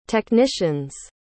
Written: technician's

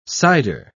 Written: sider

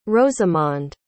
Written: rosamonde

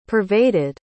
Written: pervaded

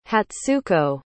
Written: hatsuko

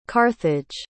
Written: carthage

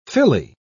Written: filly

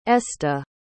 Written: esta

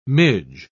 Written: midge

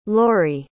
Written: lori

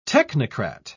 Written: technocrat

